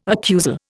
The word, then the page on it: accusal: Indian